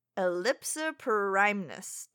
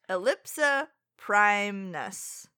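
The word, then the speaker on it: ellipsiprymnus: Species, Ellipsoprymnus. Didn't say that right. Don't give that out as your fact. – That should not be your fact. – Ellipsoprymnus. That was better.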